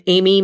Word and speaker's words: Amy: Yeah, you guys can find me on TikTok under my full government name, Amy Manlapis. That's also my Instagram. And Amy is spelled A-M-Y. My last name is M-A-N-L-A-P-A-S.